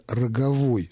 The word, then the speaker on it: роговой: И в таком случае музыка должна называться «роговой» с ударением на окончании.